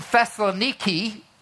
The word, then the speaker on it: Thessaloniki: So in even the early writing, the believers in Thessaloniki, is how they say it today, were troubled.